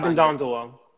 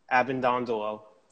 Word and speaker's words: Abbondondolo: So I can Abindondolo. – One more time? – Abindondolo. A-B-B-O-N-D-O-N-D-O-L-O.